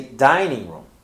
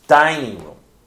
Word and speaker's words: dining room: So for example, when I say dining room, I say dining room. Yes, dining room. The first word is stressed.